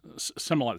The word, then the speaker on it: Smollett: Jesse Smollett, yeah. – Smollett, is that how you pronounce it? – Smollett, yeah.